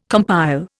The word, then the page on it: compile: Indian